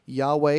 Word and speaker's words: Yahweh: Yahweh is how it is often pronounced by Christians, and that is the name Adonai Eloheinu or Yahweh Eloheinu, the Lord our God, is the name that is a covenant God.